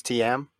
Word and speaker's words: Thiam: I think it's TM. – TM. – Go ahead. – Well, TM from Belgium.